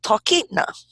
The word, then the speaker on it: Talkeetna: They're actually from a place called Talkeetna.